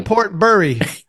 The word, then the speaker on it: Portbury: B-U-R-Y. Portbury.